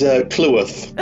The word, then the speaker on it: Clewarth: How do you pronounce your last name? – Oh, so it is Clewarth.